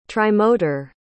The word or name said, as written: trimotor